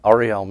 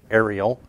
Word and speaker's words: Ariel: I'm with a ministry called Ariel Ministries. Some people say Ariel, but the A is silent in Hebrew, so we say Ariel.